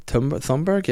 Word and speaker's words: Thunberg: Eleonora Ernman Thunberg. Yeah. Double Tintin there. – Is that how it's pronounced? Thunberg? – Thunberg, yeah. Greta Thunberg.